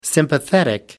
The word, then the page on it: sympathetic: American English